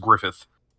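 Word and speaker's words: Gruffudd: Yeah, so that I O A N is pronounced Yoan apparently, uh, and the, and that last name that looks like Griffith or something is just Griffith, uh, evidently, and and he's Welsh, so it's kind of odd.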